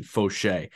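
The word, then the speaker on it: Faucher: And I looked up the pronunciation, Fauché. Like, Fauché opens a game, and he's great.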